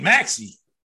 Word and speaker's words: Maxey: The kid Maxey, am I saying it right? – Yeah.